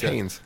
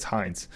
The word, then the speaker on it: Heinz: And I actually encountered different pronunciations of a name up for that center. So it's Heinz Center. – Just I thought like it's Haynes. It's Heinz.